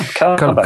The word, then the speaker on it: Calabash: Am I pronouncing it right if I say Calabash? Calabash? – Yeah, Calabash.